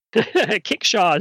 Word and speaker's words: kickshaw: Kickshaw is spelled like it sounds. K-I-C-K-S-H-A-W. At least that's the way it sounds in my dialect.